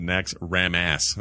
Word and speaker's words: Naxxramas: I get the old raid runs when I do Naxxramas. – I like that you pronounce it Naxxramas.